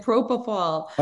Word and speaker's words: propofol: What is it, propofol? How do you pronounce that? – You named it propofol.